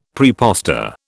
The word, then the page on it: praepostor: British English